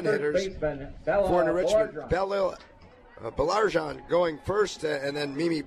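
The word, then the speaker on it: Baillargeon: Going to give people an appreciation of the pronouncing challenge. It's B-A-I-L-L-A-R-G-E-O-N. Belarjan is what we've settled on here.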